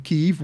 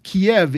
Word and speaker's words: Kyiv: And he was saying, yeah, that for those of you who are wondering, right, Matt has exclusively gone with Kyiv, which is Ukrainian, right? Yeah. Kiev is the Russian. – Right, pronunciation. – Is the Russian pronunciation of the capital. Kyiv, which we've now all come to hear more and more. Yeah, is the Ukrainian way of calling this.